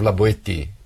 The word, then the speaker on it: La Boétie: Etienne de la Boétie. Leiam. Se escreve la Boétie.